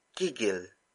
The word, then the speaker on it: gigil: This episode is brought to you by the Tagalog word gigil. Gigil. Gigil. Gigil is spelled as G-I-G-I-L.